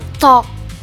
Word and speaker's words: talk: Itu bahasa Inggrisnya yang benar itu pronounce-nya talk. – Talk. – Jadi talk. L-nya lebih silent gitu, Kak.